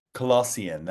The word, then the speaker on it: Colossyan: Colossyan, that's it. You got to really get your mouth around those L's and the N.